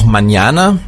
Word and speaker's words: mañana: Erstmal eine Sonderheit, Sie kennen das alle, das N mit diesem Schleier drauf, das wird ausgesprochen wie ein NJ. Ja, Sie können also als Beispiel España, kennt jeder, oder aber auch mañana, heißt morgen.